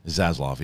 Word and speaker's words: Zaslav: Zaslav, yeah. Zaslav.